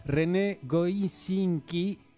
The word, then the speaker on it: René Goscinny: Sí, nacía René Goscinny, sí, no sé si lo pronuncié bien. – Sí, sí, está bien dicho.